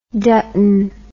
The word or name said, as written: Dutton